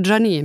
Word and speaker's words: Janet: Also wie, ich wollte gerade sagen wie Jenny. Nee, überhaupt nicht wie Jenny. Einfach Janet mit Doppel-E.